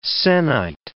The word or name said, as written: sennight